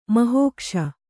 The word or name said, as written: ಮಹೋಕ್ಷ